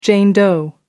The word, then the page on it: jane doe: American English